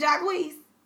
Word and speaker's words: Jacquees: Jacquees, is it Jacquees? – I don't know, girl. – You're confusing me. So I thought so until you started saying it differently. Then I'm like, have I been saying it wrong? – No, you're really saying it right.